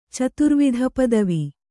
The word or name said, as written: ಚತುರ್ವಿಧಪದವಿ